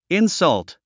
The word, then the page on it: insult: insʌ́lt; インサァウト